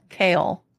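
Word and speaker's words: Chaol: The fucking, it's like chaos, but instead of an S it's an L. Kale.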